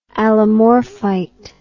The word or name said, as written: allomorphite